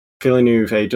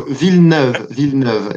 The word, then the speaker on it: Villeneuve: And I think Villeneuve does that. I've done a bad pronunciation there. – Okay, so Villeneuve is how it is.